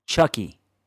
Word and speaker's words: Chucky: The discount code across the board for all the apparel is Chucky, C-H-U-C-K-Y.